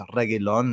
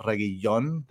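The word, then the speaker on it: Reguilón: There's just one L, so it's Reguilon, not Reguillon. That, and even on TV, I'm listening to it, and for some reason, they're not pronouncing the L.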